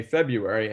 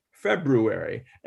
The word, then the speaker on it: February: And to hear February, and it's you know, newscasters, professionals, people who speak for a living say February, and it bugs me because there is an R there, and it should be, in my opinion, February.